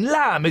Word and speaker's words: lamb: But we're not, dropping the silent B in lamb is an Americanism.